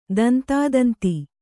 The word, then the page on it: ತಂತೋತಂತು: tantōtantu